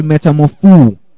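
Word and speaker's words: metamorphoō: Now, that word transformed is the Greek word metamorpho. Okay? I'll spell it for you. M-E-T-A-M-O-R-P-H-O-O. The last O is accentuated.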